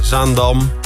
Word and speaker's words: Zaandam: Zaandam? – Dat zei ik toch? – Zaandam. – Oh, klemtoon. Je moet hier zo op je woorden en op je foutjes letten, want je wordt... – Hey, maar ga maar door. – Zaandam.